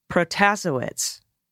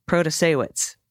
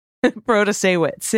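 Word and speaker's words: Protasiewicz: Hi, just a friendly correction for the pronunciation for Judge Janet in Wisconsin. Protasewicz. No, that's not right. Protasewicz. Protasewicz.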